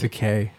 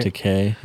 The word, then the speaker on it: Takei: Takei. It's EI, right? – Takei. Yeah. – Yeah. And he's Japanese, so EI sounds like an AY. – Takei.